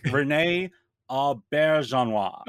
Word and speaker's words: Rene Auberjonois: Rene Auberjonois. – Oh, very good. Very good. – Is that good. – Right? Yeah. – I have no idea, but yes, that's him. – Rene Auberjonois, yeah.